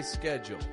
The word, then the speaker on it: schedule: In my accent, we'd say schedule. In other accents, it may sound a little more like schedule, making a sh, schedule. But not where I'm from.